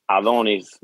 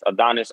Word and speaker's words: Adonys: Yo tengo Instagram, es Adonis, that's A-D-O-N-Y-S underscore Y-S. Siempre le digo a la gente que mi nombre se escribe con Y y con S, Y-S, Adonis underscore Y-S.